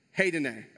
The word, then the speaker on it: hedone: It's called I wrote it down so I could pronounce it right. Hedone. Heidene, okay? That's the Greek word, heidene.